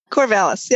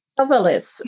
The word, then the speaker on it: Corvallis: Corvallis, yep. – Corvallis.